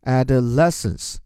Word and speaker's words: adolescence: 来来来，这个一上来就让很多人感到畏难情绪的复杂词汇 adolescence 青春期。先解决读音问题，a 发作 a，然后 d o 是的。然后重音在后面，le，所以加在一起，adolescence，青春期，the time when you are changing from being a child into an adult。